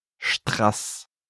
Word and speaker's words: Strass: Strass. – Do you hear the difference? The second word has a short A sound. That's why it's written with a double S.